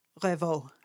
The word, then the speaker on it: Revaux: It was written by Claude François and Jacques Revaux. – Jacques? – Jacques. – And then how do you spell that? – R-E-V-A-U-X. – Revaux.